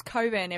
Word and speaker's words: Cockburn: It's Cockburn, everyone, despite the fact that it's spelt otherwise.